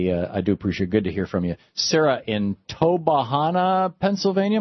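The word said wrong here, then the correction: Tobyhanna: Am I saying that right, Sarah? – Toby Hanna. – Toby Hanna.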